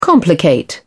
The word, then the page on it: complicate: British English